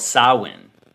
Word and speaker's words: Samhain: It used to be called something called Samhain. And like I said, I hate the way that's pronounced because it's not written like that at all.